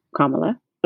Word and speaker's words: Kamala: It's actually pronounced Kamala. And the accent is on on the first syllable. It's not where he placed it and it's a common Hindu name, and it means lotus.